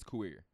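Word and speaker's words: Kuier: Well, you said it right the first time. It's Kuir.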